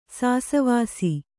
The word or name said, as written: ಸಾಸವಾಸಿ